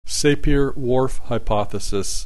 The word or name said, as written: Sapir Whorf hypothesis